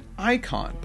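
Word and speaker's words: eikon: It's an antiquated spelling of icon. – Oh, okay. – So it's pronounced the same way even. It's just spelled funny.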